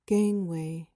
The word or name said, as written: gangway